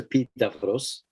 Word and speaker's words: Epidavros: The main healing sanctuary of Asclepius is Epidavros, spelled Epidaurus in English, for people who see it. But it's pronounced Epidavros.